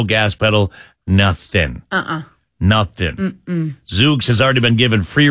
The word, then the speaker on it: Zoox: Z-O-O-X?